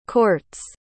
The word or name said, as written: court's